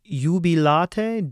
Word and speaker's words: Jubilate: Jubilate is an IU diphthong, but we do it on one syllable. You. So it's really the U vowel with an E articulation at the beginning. You. Well, you got to get to the U right away.